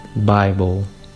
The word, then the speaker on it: Bible: En inglés la palabra Biblia se pronuncia Bible.